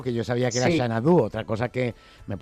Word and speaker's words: Xanadu: se pronuncia Xanadu. – Claro, claro, claro, como la película y la canción.